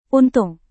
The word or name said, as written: untung